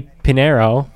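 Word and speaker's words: Pineiro: And then the kicker, Eddie Pinero, that's how you pronounce his name, got roasted because people had video of him taking selfies on the field before the game.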